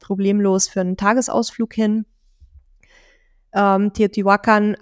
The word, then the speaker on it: Teotihuacan: Nächste Etappe, Teotihuacan oder Teotihuacan, wie es oft ausgesprochen wird, war eigentlich ursprünglich gedacht, um auszuruhen und zu arbeiten.